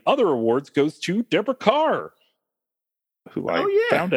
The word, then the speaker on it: Kerr: So we've been saying Kerr for the longest time. – Indeed. – But it's Carr.